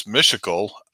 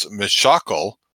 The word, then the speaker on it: Mishakal: I always thought it was Mishakal, but she pronounces it as Mishakal.